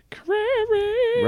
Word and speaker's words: Crary: Crary. – Rhymes with prairie.